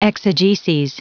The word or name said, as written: exegeses